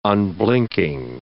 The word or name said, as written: unblinking